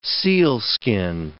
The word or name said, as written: sealskin